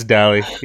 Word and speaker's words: Dally: Wait, it's just Sam Dally. It's first name, last name. – Dally, I've been saying it wrong this whole time. I'm over here saying Dale. I'm like, Dale. Hi, Dale. – No, it's Dally.